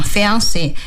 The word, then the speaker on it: fiancé: 说的是在英语里边很多法语词就原模原样的端来所以呢就像这样 Fiancé 也就这样端来了而且法语的发音重音往往在最后一个要是真正的法语呢是 fiancé 会说这样的，但是到英语里边就 fiance 就变得稍微那个往前靠了一些。